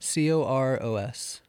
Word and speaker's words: Coros: Coros. – How do you spell that? C O R O S.